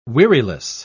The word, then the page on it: weariless: British English